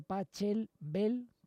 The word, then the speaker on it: Pachelbel: Bueno, era el Canon de Pachelbel, que repito, se escribe Pachelbel con B, pero que la Che ya sabéis que los alemanes la hacen ahí dura, áspera, igual que Bach, ¿no?